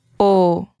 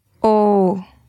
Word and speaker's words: o: The masculine article is Oh. – Could you repeat that? – Oh. – See how that isn't oh or ah or something like that. It's oh. Oh, brilliant. It's also important to know that in a sentence, o is pronounced almost u, as in o livro é meu.